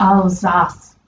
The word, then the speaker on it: Alsace: Apparently it's Alsace. – Alsace? – That's the way this guy like spelled it phonetically.